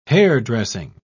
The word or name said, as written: hairdressing